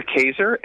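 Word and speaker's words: Kaczor: It's written by Christopher Kaxer, and I may have said that wrong, but the article is titled, did I say it right? – It's Dr. Kaxer,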